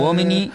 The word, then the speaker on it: uomini: Uomini se pronuncia.